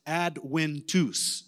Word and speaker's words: Adventus: It looks like Adventus. And she's like, no, it's Adventus. Is that right, Gideon? Adventus? That's right. So the V apparently is a W in Latin.